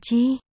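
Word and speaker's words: chi (鸡): Trong tiếng Trung đọc là chi, gà, sẽ là chi.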